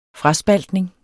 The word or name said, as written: fraspaltning